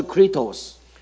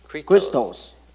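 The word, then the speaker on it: Kritos (χρηστός): 它的念法是 Kritos. – Kritos. Kritos in Greek. – 就是在希腊文跟基督的翻是一样的。 – Pronunciation is exactly the same as Christ.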